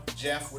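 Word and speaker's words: Geoff: I'm The Jeff with a G.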